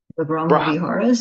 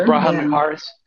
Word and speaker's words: Brahmaviharas: The Brahmaviharas, so care. – Yeah, the Brahmaviharas.